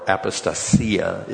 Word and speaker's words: apostasia: It's apostasia, or apostasia is the way you're supposed to pronounce it.